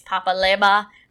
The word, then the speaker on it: Legba: And the black woman basically stitches his video and is like, you're not pronouncing it right. First of all, it's Papa Legba. We don't say the G.